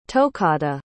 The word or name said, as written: tokahda